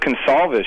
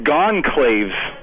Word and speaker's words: Gonçalves: I admit, I only know this because my wife's Portuguese, but it's pronounced Gonçalves, right? And so, because the C has a little stupid S thingy under it, I don't even know what it's called. But I think he said Gonclaves.